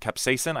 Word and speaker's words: capsaicin: There is something called capsaicin. I think I'm uh-huh, pronouncing uh-huh, that right, correctly yep.